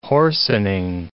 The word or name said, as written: hoarsening